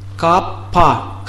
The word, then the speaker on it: капха: Итак, капха. Капа. Х не читается, поэтому как капа.